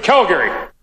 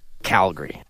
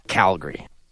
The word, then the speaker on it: Calgary: That was Saskatchewan Premier Scott Moe, and that is the way Calgary is typically pronounced in his province. But if you're from Alberta, you say it like this. – Calgary. Calgary.